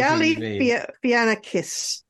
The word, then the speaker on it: Galifianakis: Galifianakis? – I know what you mean, and I wouldn't be able to pronounce it either, but right. I know Galifianakis.